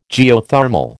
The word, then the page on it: Geothermal: American English